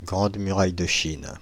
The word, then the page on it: Grande Muraille de Chine: France (Île-de-France); [ɡʁɑ̃d my.ʁaj də ʃin]